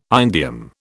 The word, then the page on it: indium: British English